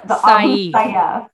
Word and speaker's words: Sayyaf: The Sayyaf.